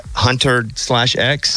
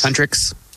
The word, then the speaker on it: Huntrix: but I, Hunter slash X? – Huntrix.